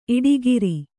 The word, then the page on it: ಈಡುಕಾರ: īḍukāra